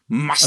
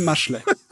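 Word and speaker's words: Mashle: Mushel. Also nicht die Maschle.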